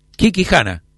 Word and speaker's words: Kikijana: Kikijana, correcto, sí, lo dije bien.